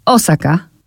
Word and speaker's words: Osaka: Ja teraz zwróciłam uwagę, jak pan zaakcentował, bo ja powiedziałam chyba Osaka, a pan. – Osaka. – Osaka, a ja powiedziałam Osaka. – No tak, ja już czasami nie do końca wymawiam może w, z poprawnym naszym polskim akcentem te nazwy, dlatego że widzę je i słyszę je już y, w, y, po japońsku. Czyli po japońsku jest Osaka.